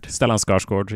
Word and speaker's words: Stellan Skarsgård: Stellan Skarsgård yeah there you he's go.